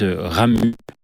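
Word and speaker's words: Ramuz: C'est l'auteur se Ramu. Avec un Z à la fin, mais les Suisses ne prononcent pas le Z.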